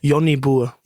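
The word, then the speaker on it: Jonnie Boer: Und dann den spricht man nicht so aus. – Sag mal, wie man es ausspricht. – Johnny Burr. – Johnny Burr, sehr gut.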